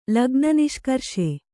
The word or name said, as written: ಲಗ್ನನಿಷ್ಕರ್ಷೆ